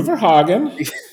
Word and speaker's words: VerHagen: You mean Drew Verhagen? – yes. – I'm just trying to honor his Dutch heritage with the closest pronunciation that I can muster.